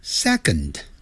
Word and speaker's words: second: 好，它有两种写法，一个用数字一再加个 st，就是一加 st，但英文呢叫念成呢 first， F-I-R-S-T， first， first，跟着我这样念，第一 first，第二 second， second，不要念成 second， second，那个的要念出来，你可以切掉它， second 或者 second，那个的轻轻念一下，点到为止。